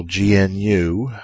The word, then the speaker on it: GNU: That's capital GNU with a hard G.